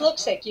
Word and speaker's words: Luksik: Oh, Miss Looksick, you look sick.